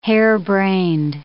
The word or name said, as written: harebrained